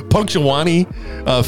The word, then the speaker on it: Punxsutawney: How do you say that? Punxsutawney? Punxsutawney? – Punxsutawney. Yeah, now Punxsutawney.